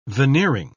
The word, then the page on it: veneering: British English